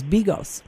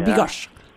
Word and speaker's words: bigos: which was bigos. – Oh, yeah. – Or bigosh. I think that's how you say it, which I absolutely adore because I love choucroute, so it's essentially the same thing.